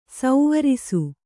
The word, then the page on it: ಸ್ವರಸ: Kannada